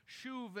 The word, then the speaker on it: shuv: The Hebrew word for repentance, it's pronounced shuv.